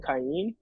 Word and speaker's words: Kayin: Because like my name's pronounced Kayin, and like Kayin. All through like middle school, like high school, all that stuff, people would just pronounce it wrong.